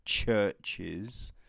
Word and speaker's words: CHVRCHES: Like, alright, so there is a Scottish band called Churches. It's spelled CHVRCHES.